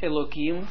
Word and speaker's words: Elokim: Ook het woord Elokim spreken we niet met een H.